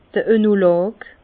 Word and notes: d'r Oenolog: Bas-Rhin; Herrlisheim

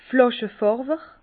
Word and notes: Flàschefärwer: Bas Rhin; Herrlisheim

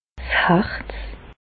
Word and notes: 's Harz: Bas Rhin; Reichshoffen

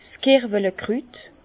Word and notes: 's Kerwelekrüt: Bas Rhin; Herrlisheim

